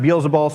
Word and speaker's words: Beelzebul: Sometimes it's pronounced Beelzebul, sometimes it's pronounced Beelzebub.